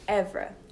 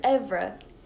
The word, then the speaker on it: oeuvre: Evra. Okay. My wordlet is Evra, and that's spelled O-E-U-V-R-E, and it's French.